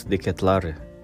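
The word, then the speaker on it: De Ketelaere: He's Charles de Ketlare. That's how uh, to pronounce his name from the video uh, circulating in, in the Twitter.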